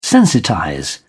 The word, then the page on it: sensitize: British English